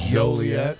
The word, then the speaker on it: Joliet: I believe it's a soft J. It's pronounced Joliet.